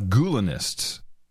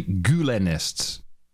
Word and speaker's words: Gulenists: run by a lot of Gulenists. I should pronounce that properly Gulenists, uh, who were educated in the Gulen schools run by Fethullah Gulen.